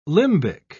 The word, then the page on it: limbic: British English